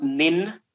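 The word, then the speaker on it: nin (忍): it's pronounced nin n-i-n nin and it means like uh,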